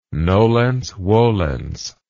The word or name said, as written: nolens volens